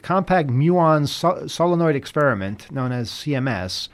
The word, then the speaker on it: muon: And the website, the compact, how do you say this word? M-U-O-N? – Muon.